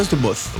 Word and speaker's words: The Moth: Ça se prononce comme ça, The Moth. – Non, c'est bien The Moth. – Oh, The Moth. The Moth. – Le TH est parfaitement prononcé, bravo.